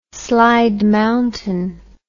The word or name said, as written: Slide Mountain